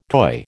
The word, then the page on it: toy: American English